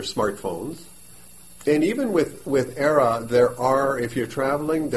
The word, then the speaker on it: Aira: Aira. It's spelled A I R A. So it's the word air with an A on the back.